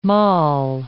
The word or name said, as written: moll